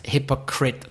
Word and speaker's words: hypocrite: I-T-E, but it's hypocrite. That's a really weird one. Yeah. With a silent E at the end. And the I is pronounced I, not I. And it's really funny because the word is so hypocritical. Because there's the rule, I-T-E, it, but not hypocrite.